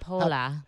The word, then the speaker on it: Hepola: Hepola.